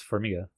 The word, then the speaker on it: Farmiga: And you have Vera Farmiga, who, did I say that right? – I just thought it was Farmiga.